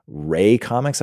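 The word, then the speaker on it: Rae Comics: Ray Comics, I believe is how you say it. R-A-E Comics.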